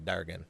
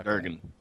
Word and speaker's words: Dergon: I like how you just say Durgan, like as if that's just the way you pronounce dragon. – Yeah. – How do you spell Durgan? – D-E-R-G-O-N. – D-U-R or D-E-R? – D-E-R.